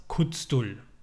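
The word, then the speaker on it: Khuzdul: Die Sprache der Zwerge ist das Kuzdul das klingt ja wie Kotzen, aber kurz Kuzdul, welches neben der gesprochenen Sprache Agla auch aus einer Gestensprache, dem sogenannten äh, Iglischmeck, habe ich das richtig ausgesprochen?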